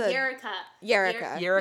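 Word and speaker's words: Yrica: More of just talking about the character of how do you say her name? Is it Yikra? – You listen to the Yerika. – Yeah. Okay. – Yeah. Alex, listen to the audio. – It's perfect. Like Erica, but with a Y.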